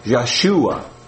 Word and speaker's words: Yeshua: Yeshua, okay.